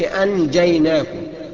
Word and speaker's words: أنجيناكم: في كلمة، أنجيناكم، أنجيناكم، أنجيناكم، أنجيناكم، ما تقولش أنجيناكم، لا، لازم تخفيها، اديها الإخفاء اللي هو بمقدار حركتين اتنين، نقول أنجيناكم. طيب في كلمتين، أنجاؤكم، أنجاء، أنجاؤكم، هكذا، شوف النطق، أنجاء، حركتين اتنين بتغن فيهم وبتتمكن كويس في قراءة الإخفاء.